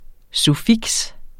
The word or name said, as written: suffiks